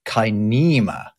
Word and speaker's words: kinema: It's spelled same as cinema with a K, but it's pronounced kinema.